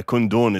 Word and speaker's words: Kundun: Yeah, Kundun, it's actually pronounced.